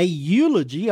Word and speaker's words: eulogy: A eulogy, although it's not pronounced ev, it comes from the same Greek word, good, ev.